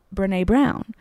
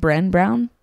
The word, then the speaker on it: Brené Brown: and the cards had a quote from Brené Brown, in which when I got the card, I pronounced her name Bren Brown.